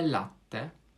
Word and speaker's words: latte: Z włoskiego kafe latte lub w wersji zbitej kafe latte z wyraźnym podwójnym T i podwójnym L oraz akcentem na pierwszą sylabę słowa latte to nic innego jak każda kawa z mlekiem.